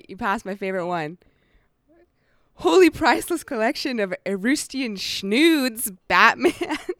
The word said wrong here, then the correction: Etruscan: Yeah, Etruscan. I think that's Etruscan. It's spelled E-T-R-U-S-C-A-N.